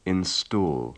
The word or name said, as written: install